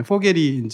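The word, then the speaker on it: Vogel: 포겔이 이제 새라는 뜻이니까. 근데 이 사람은 독일어를 모르잖아요. 그러니까 대충 포겔만 그냥 줄여서 포그라고 해. 이렇게 한 거죠. 포겔은 심지어는 V로 시작해요. 독일어로는. 근데 F 발음이니까 들리는 거는 그냥 F에서 포겔에서 잘라서 포그라고 해가지고 FOG를 지 마음대로 친 겁니다.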